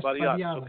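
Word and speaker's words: Batiatus: The name of Batiatis? I don't know if I pronounced that right. – Yes, Batiatis. Okay, thank you. Thank you for clarifying over here.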